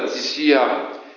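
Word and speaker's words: thysia (θυσία): Or, ces mots-là, thysia, ou thio, pour prononcer comme ça, qui s'écrit T-H-Y-O ou T-H-Y-S-I-A, c'est la même racine que le terme thymiama, qui veut dire le parfum.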